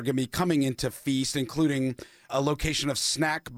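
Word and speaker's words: Pheast: It's called Feast, which is spelled P-H apostrophe E-A-S-T. So it's sort of a combination of Far East and Feast.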